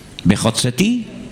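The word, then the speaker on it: vehotzeti: Si os dais cuenta, en cada una de estas cuatro promesas, desde la primera hasta la última, al final de cada una de estas palabras se emplea la misma sílaba. Sacaré, vejotzeti, libraré Vejisaltí, redimiré Vegaltí y otomaré Velacachtí. Las cuatro terminan en ti, ti, ti, ti.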